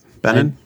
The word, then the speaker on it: Benin: Yeah, Benin.